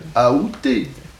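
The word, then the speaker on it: aoûté: La prononciation qui est considérée comme correcte, c'est la prononciation avec A. Aouté.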